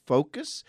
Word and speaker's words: Phocus: That's spelled P-H-O-C-U-S, Focus.